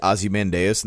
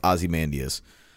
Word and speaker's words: Ozymandias: Ozymandias, I said that wrong. I always read that Ozymandias in the comics, but it's Ozymandias.